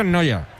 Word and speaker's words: Neuer: Yo digo, se pronuncia, es que es Neuer, pero se pronuncia casi igual. Sí, sí. – Sí, sí, se pronuncia Noya.